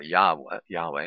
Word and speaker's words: Yahweh: And you could search in Google for why the V in Yahweh, not the W in Yahweh, or Yahweh, is how they actually should be pronouncing it, putting the accent on the first syllable.